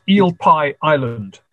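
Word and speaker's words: Eel Pie Island: Now, the El Pai, I've never been by it. Did I pronounce that right? – No. It's El Pai Island.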